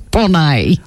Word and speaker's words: Bonet: It's Bonet. Oh. Which is, you know... Do you know her? Do you know Lisa Bonet? – Lisa Bonet Car Yard. Sells Kingswoods, but probably in France it's Bonet.